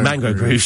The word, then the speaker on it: Mango Groove: Ian Dale could name you all the members of Mungo Groove, I'm sure. Mango Groove. Sorry, I can't get the name right.